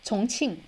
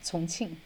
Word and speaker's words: Chongqing: Chongqing, Chongqing.